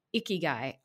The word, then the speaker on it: ikigai: And you start off by talking about moving into excellence – from excellence into ikigai. I hope I'm pronouncing that correctly. – Well, that's very good.